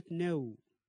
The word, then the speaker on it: know: حرف ال K أيضا في كلمة know, knowledge, kneel, knock, knife, numb.